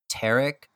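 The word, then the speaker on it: Terek: The first one that came through was the Tarek. I think I'm saying that right, but that's how I would pronounce it.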